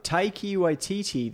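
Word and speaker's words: Taika Waititi: Okay, next up, Taiki Waititi, the director of Flight of the Conchords.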